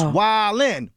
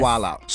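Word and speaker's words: Wild 'N Out: It's Wild'n. – Not Wild Out. So, right. Wild'n. Say it right.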